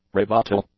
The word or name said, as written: rebuttal